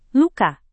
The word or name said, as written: luka